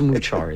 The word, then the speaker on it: Muchar: It looks like Machar. But it's Muchar is how they pronounce it in India.